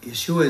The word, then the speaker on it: Yeshua: Now pray this in the precious name of Yeshua, Amen. Amen, brothers and sisters. Yeshua is how you say Jesus in Hebrew.